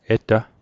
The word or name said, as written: eta